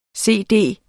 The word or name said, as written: cd